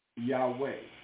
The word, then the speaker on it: Yahweh: In your Bible, the name used in Torah, the name used in the English is Jehovah or Yehovah, because there's really no J, Yehovah. But in the Hebrew, it's pronounced Yahweh.